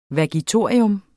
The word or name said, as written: vagitorium